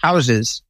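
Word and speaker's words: Houzez: Number one on our list to talk about is a WordPress theme that I believe, if I say this correctly, is pronounced houses, but with Z's, H-O-U-Z-E-Z.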